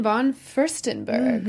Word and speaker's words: von Furstenberg: But I do think they all got humbled, speaking of that, when they got to meet guest judge Diane von Furstenberg.